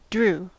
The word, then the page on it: drew: American English